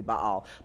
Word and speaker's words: Baal: Belteshazzar means Baal's servant, or Baal, if you will, but pronounced technically Baal.